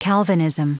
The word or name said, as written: calvinism